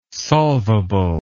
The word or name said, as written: solvable